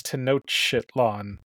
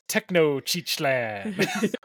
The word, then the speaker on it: Tenochtitlan: Kendra said, what is Tenochtitlan? And Josh said, what is Tecnochichlan? Which is incorrect. And they determined that Kendra left a T out of Tenochtitlan, which is a hard one to pronounce for English speakers and not Nahuatl speakers.